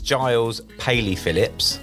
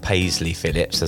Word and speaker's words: Giles Paley-Phillips: It's Giles Paley-Phillips, not Paisley-Phillips, as I sometimes get, or Paley-Phillips. Paley-Phillips.